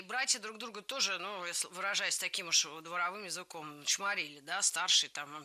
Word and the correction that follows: дворовым: Дворовым языком – это неверно, правильно, дворовым. В прилагательном, образованном от существительного «двор», ударение ставим на второй слог – дворовый, дворовая, дворовыми.